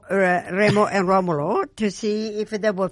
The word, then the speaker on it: Romolo: Is That's it, in Italian. Remo and Romolo. – Is the correct pronunciation.